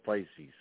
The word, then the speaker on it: Pisces: Piscus, I think it is. Is that how you say it? Piscus? – Yeah, I think it's called Pisces. – Oh, Pisces. That right.